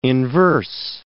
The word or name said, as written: inverse